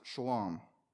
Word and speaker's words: shalom: The peace of God that is being referred to here is it's a Hebrew word pronounced shalom.